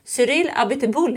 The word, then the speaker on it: Cyril Abiteboul: Cyril Abiteboul heter han, va? – Mm, perfekt uttal.